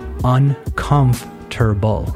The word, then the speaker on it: uncomfortable: What you want to do is divide it into four different parts uncomfortable, uncomfortable, with the emphasis on the second part, uncomfortable.